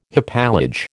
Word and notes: hypallage: American English

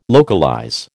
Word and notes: localize: American English

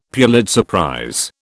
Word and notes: Pulitzer prize: British English

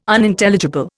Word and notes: Unintelligible: Indian

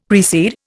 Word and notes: precede: Indian

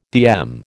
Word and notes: DM: American English

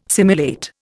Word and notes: simulate: Indian

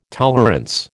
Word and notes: tolerance: American English

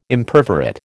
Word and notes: Imperforate: American English

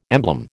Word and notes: emblem: American English

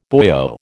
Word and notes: boyo: American English

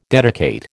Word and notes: dedicate: American English

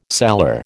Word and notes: cellar: American English

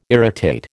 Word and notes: irritate: American English

